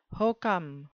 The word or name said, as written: hokum